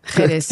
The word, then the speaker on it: Jerez: Jerez. Stimmt das? Ist ja richtig ausgesprochen. – Ja. – Jerez, ja.